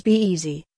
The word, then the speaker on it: Bez: Rebranded as Bez, pronounced as Be-Easy, in 2017, the brand now specializes in sleek smartphone covers made of synthetic leather, priced from £3.99, €4.50.